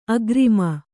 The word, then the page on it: ಅಕ್ರಮ: akrama